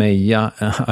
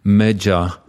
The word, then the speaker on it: Meja: It's called Yellow Ribbon, and Mayai, I pronounce it in Swedish. It's supposedly pronounced Meja in English, M-E-J-A.